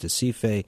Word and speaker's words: Recife: So this is the picture that you see in urban cities like Recife, pronounced Recife, by the Brazilians, where you have the favelas, which are poor urban environments.